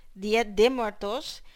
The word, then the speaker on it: Día de Muertos: Día de Muertos, as Patrimonio de la Humanidad.